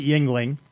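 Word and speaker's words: Yuengling: People from Pennsylvania that I've talked to pronounce it Yingling.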